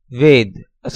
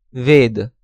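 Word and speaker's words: Ved (वेद): Usual transliteration, V-A-R-U-N-A. Sanskrit pronunciation, Varun. Hindi pronunciation, Varun. Foreigner's pronunciation, Varuna. Next row, Hindi-Sanskrit word, Ved, a scripture. Usual transliteration, V-E-D-A. Sanskrit pronunciation, Ved. Hindi pronunciation, Ved. Foreigner's pronunciation, Veda. Next row, Hindi-Sanskrit word, Ram, a hero. Usual transliteration, R-A-M-A or R-A Macron, M-A.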